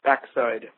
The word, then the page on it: backside: American English